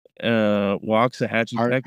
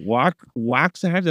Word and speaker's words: Waxahachie: Waxahachie, Texas. – Yeah, you did it good, man. You pronounced it right. – Oh yeah, Waxahachie.